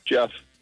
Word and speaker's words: Jeff: Jeff.